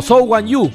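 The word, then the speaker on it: Zhou Guanyu: No, la curva 1 del Gran Premio de Gran Bretaña en Silverstone, el accidente del chino Wang Yu Su, bueno, o Zhou Wan Yu, porque en realidad se pronuncia primero el apellido en el idioma mandarín.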